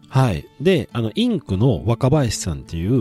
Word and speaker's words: INQ: それぐらい、インク、はい、インク、ごめんなさいです。聞いてる方に説明すると、株式会社インク、 INQ と書いて、はい、インクという会社の代表が若林さんですね。